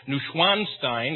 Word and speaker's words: Neuschwanstein: Just across the valley and up the side of the mountain is Schloss Neuschwanstein, or as heard many people pronounce it over there, Neuschwanstein is how our guide pronounced it.